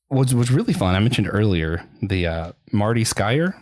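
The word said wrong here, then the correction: Sklar: You said how I say the name correctly. – Skalar. – Yeah. – Oh, Skalar. – Where's the L? Okay. Anyway, I forgot the L, I guess.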